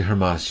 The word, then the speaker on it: Hermas: It's actually Hermas, you said it right the first time.